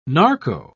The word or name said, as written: narco